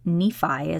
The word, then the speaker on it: Nephi: In the Book of Mormon, it is broken up into books, as is the Bible, and the second book is called Second Nephi, is how it's pronounced, N-E-P-H-I.